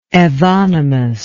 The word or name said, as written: evonymus